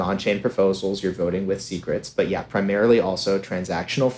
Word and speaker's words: SCRT: So secret, which is SCRT, yeah, but we pronounce it secrets. So I stake my secrets, I send you secrets.